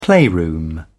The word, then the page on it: playroom: British English